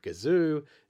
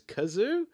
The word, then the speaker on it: Gazoo: We also establish a running joke where Gazoo's name is mispronounced as Kazoo.